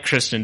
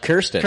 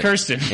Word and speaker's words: Kirsten: fact, I didn't like Kristen Dunst before this. Yeah. – You couldn't even pronounce her name right. Her name's no. – Kirsten. – Kirsten. – And you called her Kristen.